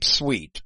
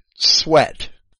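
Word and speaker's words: Swete: The Codex Alexandrinus, according to Brenton, and also according to Sweet, and I'll pronounce his name Sweet because it's S-W-E-T-E, and I don't want to pronounce it Sweat.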